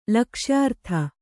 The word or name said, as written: ಲಕ್ಷ್ಯಾರ್ಥ